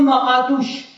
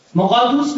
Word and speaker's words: مقادوس: یوم کیپور بزرگترین روزه یهودیان است که با نامهایی چون یوم عدیل یعنی جلیل و با عزت، یوم مقادوش، مقادوش به جای شسه بذارید، مقادوس بگید، مقدس، روز مقدس، یعنی روز، و یوم عاشورا هم مسما می باشد.